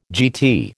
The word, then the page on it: GT: American English